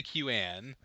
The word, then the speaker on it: QAN: But would it be pronounced can or quan? – You just say QAN. I don't think you try to pronounce it as one word.